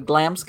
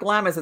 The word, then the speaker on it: Glamis: I want to pronounce this correctly because I've been spanked before for saying it the wrong way. Glam's Castle. In Scotland. – Glamis. – Well, that somebody told me, no, it's not Glamis, it's Glams. And I'm like, G-L-A-M-M-I-S, Glamis.